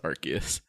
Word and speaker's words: Arceus: How, like, using those pronunciations is enough to convince me that that is how you pronounce Arceus. – Because they all are arc.